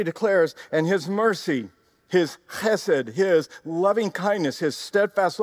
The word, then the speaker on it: chesed: That is his chesed. That's how you say it in the Hebrew. You got that guttural chesed. It's like you've got a cold or something. You got to get it out.